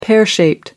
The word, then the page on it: pear shaped: American English